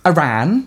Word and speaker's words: Arran: I get Aran because it's spelt A-R-R-A-N. – Yeah. – Which is like the Scottish island.